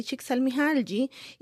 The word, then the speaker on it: Csikszentmihalyi: Eh, así es que él impulsa ese movimiento junto a Mihaly Csikszentmihalyi y otros colaboradores.